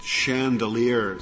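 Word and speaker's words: Chandeleur: I take it from the way you're pronouncing the word that Louisianans pronounce it Chandelier Sound, even though the last syllable is spelled L-E-U-R? – Yes, sir.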